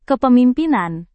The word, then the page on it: kepemimpinan: indonesian